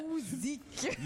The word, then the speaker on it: Usyk: Usyk.